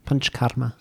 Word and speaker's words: Panchakarma: Panchakarma.